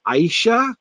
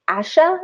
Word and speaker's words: Ayesha: Like, cause it's A-Y-E-S-H-A, right? Is it, how many syllables? Is it Ayesha or is it Aisha? Or what do you, I'm not really confident. – I think I read in looking it up that at least for Haggard, he said it should have been pronounced like Asha or something like that.